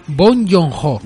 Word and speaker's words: Bong Joon-ho: Cinefagia, pues bueno, se hace presente con el éxito internacional de Parásitos, esta película de Bong Joon-ho. Y hoy nada, daremos un pequeño repaso a la filmografía para completistas de Bong Joon-ho y... Bong Joon-ho, ¿no es? Sí. Y bueno. – Bong Joon-ho.